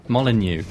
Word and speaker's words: Molyneux: Molyneux.